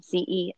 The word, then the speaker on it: Celan: So Bono had been reading this poet, Paul Celan, I think is how you pronounce his last name, Celan.